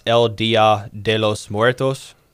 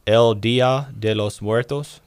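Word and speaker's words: El Día de los Muertos: And I think it's pronounced El Dia de los Muertos. It's also known as Day of the Dead. – Say it again. – El Dia de los Muertos.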